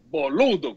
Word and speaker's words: boludo: O cara diz boludo com mais ênfase na segunda sílaba ou pelotudo na terceira sílaba, não é?